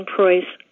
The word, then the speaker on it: Preuss: Hello, my name is Margie Preuss. Margie with a hard G and Preuss like voice.